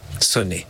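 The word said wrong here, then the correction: Sonne: Ah, Zone.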